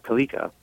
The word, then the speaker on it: Kaleka: It's uh, Kalika. Kalika, uh, but it can also be Kalika. – Kalika. – Is the uh, that's the that's the pronouncement, you know, pronounce, pronunciation in Indian.